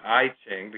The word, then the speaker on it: I Ching: And like, for instance, in the case of the I Ching, which is sometimes pronounced I Ching because it's two words, the letter I followed by the word Ching, which means book of changes in Chinese.